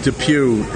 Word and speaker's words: Depuy: Depew. It's actually spelled Depuy. It's like D-E-P. – It's French. – U-Y, but they pronounce it Depew.